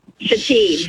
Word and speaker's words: Shithead: I interviewed this person for a position, and on her resume, it was S-H-I-T-H-E-A-D, pronounced Shateed.